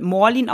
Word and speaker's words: Malin: Nein, aber das Geile ist, Marlin ist ein schwedischer Name. Also Marlin im Schwedischen hat eigentlich so ein Kreis auf dem A und wird Morlin ausgesprochen.